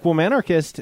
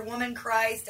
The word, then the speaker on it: Womb Anarchist: And a song like Womb Anarchist is, and I don't know if I'm even pronouncing that right. I don't think I've ever heard anyone. – You got it right.